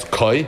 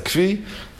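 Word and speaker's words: Kvi (כוי): So some people pronounce it as Koi. Yeshiva, it's a Kvi. So, Vashteitzach, we're going to call it a Kvi.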